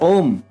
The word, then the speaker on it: Om: Just before recording the song, he asked my grandfather, Gosai Babu, what is the correct pronunciation of Om? My grandfather replied, Ustad Ji, it is Om and not Om. He clarified his doubt and explained him how to stress on Om, that is A-U-M and not O-M.